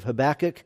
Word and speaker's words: Habakkuk: If you're wondering how to say Habakkuk, the way I learned it is it's the same as tobacco. I don't know why that's what somebody said, but that's how you say it.